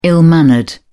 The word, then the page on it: ill-mannered: British English